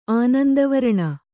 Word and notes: ಆನಂದವರಣ: kannada

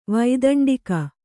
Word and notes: ವೈತಂಡಿಕ: vaitaṇḍika

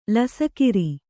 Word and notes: ಲಸಕಿರಿ: lasakiri; kannada

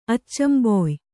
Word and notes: ಅಚ್ಚಂಬೊಯ್: kannada

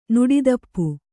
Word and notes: ನೆಡುತೋಪು: neḍu tōpu

